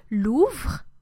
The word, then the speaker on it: Louvre: This letter will also be mute, simply not pronounced in most regions of France, actually, when it's in final position. It's just not needed, not necessary. You will read Louvre, France, Anne.